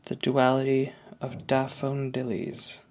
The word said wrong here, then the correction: Daffodowndillies: Later, I'll learn this play is actually called The Duality of Daffodowndillies. But, like, come on. Seriously expect me to nail that pronunciation on a cold read?